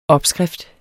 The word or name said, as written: opskrift